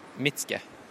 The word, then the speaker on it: Mitsuke: Byen heter Mitsuke. Uttales eh, Mitske.